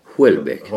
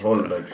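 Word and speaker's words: Houellebecq: Holbeck. – Holbeck. Okay. – Du måste kunna uttala det rätt. Holbeck låter som Paul Holländer.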